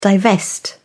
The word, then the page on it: divest: British English